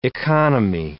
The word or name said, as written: economy